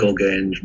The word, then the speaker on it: Gogaine: Gogaine rhymes with cocaine, so you can guess it's a stimulant.